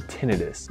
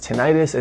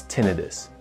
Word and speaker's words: tinnitus: So for that reason, the medical field pronounces it tinnitus. It's commonly pronounced as tinnitus, and that's okay. So what you need to know is that both pronunciations are acceptable, but there's one that's technically appropriate, and that's tinnitus.